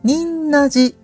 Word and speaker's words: 仁和寺: 仁和寺と、呼ばれていますけれども、と仁弁に二と書いて仁と読む漢字ですね。その仁という漢字に平和の和と書いて仁和寺さんです。